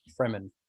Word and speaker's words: Fremen: Was it the Freeman or the Fremen? – Fremen, getting him on side.